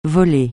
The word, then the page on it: volcanologue: francais